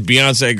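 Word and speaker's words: Beyoncé: What's going to happen to this Beyonce girl? – How do you even pronounce her name? It's got an accent on it. It's Beyonce. Bizarre. Beyonce.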